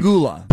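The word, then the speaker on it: Pegula: Pagula.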